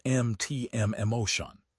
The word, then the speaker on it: MTM Motion: The MTM Association has released a tool called MTM Motion, pronounced MTM Emotion.